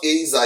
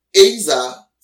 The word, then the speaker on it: ezer: The Hebrew word there is Azer. That's E-Z-E-R. And it's pronounced Azer. It's pronounced A-Y-Z-E-R. So A-Y-Z-E-R. That's how you pronounce Azer, but it's spelled E-Z-E-R.